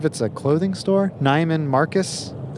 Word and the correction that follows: Neiman: Neiman Marcus.